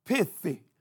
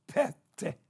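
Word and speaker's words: pete (פֶּתִי): It looked like it should be pronounced pithy or pithai if that I was loud. All right, but it's not. It is pronounced pete, pete, okay?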